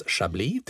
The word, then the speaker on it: Chablis: Så att det vi lär oss nu då när vi pratar Chablis, det är att det uttalas Chablis till att börja med.